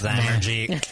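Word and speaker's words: Zymurgy: It's pronounced Zymergy.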